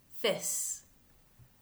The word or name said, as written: Phys